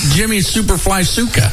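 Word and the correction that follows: Snuka: Snooka. Okay. Pronounce it correctly.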